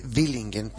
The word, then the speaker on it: Villingen: Se escribe Villingen, pero en alemán la V se pronuncia F, Villingen.